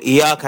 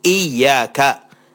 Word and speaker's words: إياك: يقول إياك نعبده، ما يشدد الياء، يجب أن يقول إياك، فيعرف الإنسان هذه الأمور.